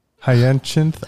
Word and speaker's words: Hyacinth: Hyacinth. – Yeah. – H-Y-A-C-I-N-T-H. Bridget. She's an author of Leading the Workforce of the Future. Keynote speaker. She posts amazing stuff on LinkedIn. That's Bridget H-Y-A-C-I-N-T-H.